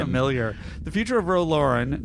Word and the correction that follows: Laren: Laren. – Laren. Really? – Roe Laren, yeah. – How do you spell it? – L-A-R-E-N.